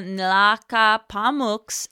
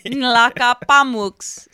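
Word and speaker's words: Nlaka'pamux: Chief Michel of the Nlacapamooks at Lytton, British Columbia. – You pronounced that perfectly. – Nlacapamooks.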